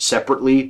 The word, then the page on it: separately: American English